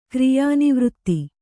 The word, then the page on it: ಕ್ರಿಯಾನಿವೃತ್ತಿ: Kannada